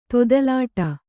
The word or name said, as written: ತೊದಲಾಟ